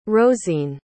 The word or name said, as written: rozene